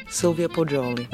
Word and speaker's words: Sylvia Poggioli: Sylvia Poggioli.